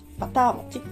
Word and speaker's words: 牛酪餅: の酪餅と書いて牛酪餅バター餅っていうふうに読み仮名が振ってあります